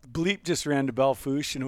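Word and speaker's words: Belle Fourche: He just left and ran to Belfourche. Is that how you say it? – Belfourche.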